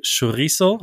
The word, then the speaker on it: Chorizo: Für, für Spanien, wenn man ähm, Fleisch mag, dann eine Chorizo. Habe ich das so richtig ausgesprochen? Ja, sehr gut.